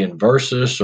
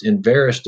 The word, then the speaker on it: Enverus: I want to say Inversus or in, it just Enverus doesn't roll off my tongue. – Yeah, one of the easiest ways to remember it is it rhymes with embarrass.